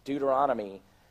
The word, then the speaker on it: Deuteronomy: And of course, I was like, "It's actually pronounced Deuteronomy."